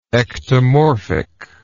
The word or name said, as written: ectomorphy